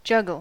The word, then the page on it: juggle: American English